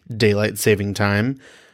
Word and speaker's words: daylight saving time: There is no S at the end of it. So if anyone out there is listening to this and you like daylight saving time, well, you better fucking say it right.